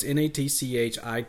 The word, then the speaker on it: Natchitoches: So for those who don't know, it's Natchitoches.